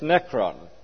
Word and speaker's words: nekron: I suppose if you wanted to give an English pronunciation to them, they are anastasis nekron.